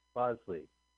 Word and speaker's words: Bosley: Bosley.